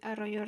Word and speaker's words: Arroyo: Je m'appelle Hania Benay Arroyo Rojo. Votre nom, comment ça s'écrit A-R-R-O-Y-O.